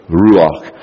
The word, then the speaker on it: ruach: And then the third one down is actually how it's pronounced, and the little accent mark on the first syllable, ruach.